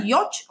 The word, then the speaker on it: yacht: Ah, es yacht o ah, yacht. sí, sí, sí. yacht. Yacht, así, yacht.